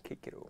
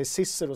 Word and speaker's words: Cicero: Ah, Kickero. För att det uttalas med K. Mm. Så att alla som går runt och säger Cicero säger fel.